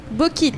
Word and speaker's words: Bokit: Bokit. Ça s'écrit B-O-K-I-T, tout simplement.